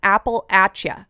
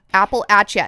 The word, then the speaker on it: Appalachia: I said Appalachia, and somebody from this area in the U.S. commented and said it's Appalachia, Appalachia. And then I looked into it, and that is the way that locals say it.